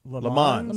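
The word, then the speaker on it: Le Mans: LeMans.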